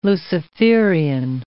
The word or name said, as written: Luciferian